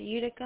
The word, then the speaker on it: Utica: How do you pronounce it? Utica, and the other one is Ithaca? – Yeah.